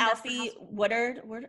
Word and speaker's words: Alfre Woodard: Someone who I feel like is that kind of awkward is Alfre Woodward. How do you pronounce her name? She was in Alfre Woodard. Yeah.